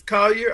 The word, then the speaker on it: Collier: Nafisia Collar? I hope I'm not mispronouncing the name. But Collar, right? Collier or Collar? – It's Collier. I think it's Collier.